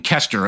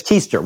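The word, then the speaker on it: Koester: It seems to be on a slightly less rigorous schedule than the podcast, which is weekly, but you do it with Megan Kester, if I'm pronouncing her name right. – I think it's Keister. Well, I know it's Keister.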